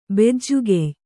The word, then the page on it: ಬೆಜ್ಜುಗೆಯ್: Kannada